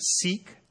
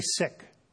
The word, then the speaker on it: Sikh: You may be familiar with the Sikh religion, and for manners, we often pronounce it Sikh. They say Sikh, and Sikhism comes out of India.